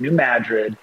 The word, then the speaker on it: New Madrid: It looks like Madrid, but that's how you say it in Missouri. It's New Madrid.